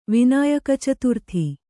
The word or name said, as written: ವಿನಾಯಕಚತುರ್ಥಿ